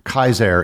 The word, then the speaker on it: Caesar: Caesar and Cicero would technically be pronounced Kaiser and Cicero, but we say Caesar and Cicero because that's what everybody knows them as, and it would just be pretentious and confusing to pronounce them in their original Latin.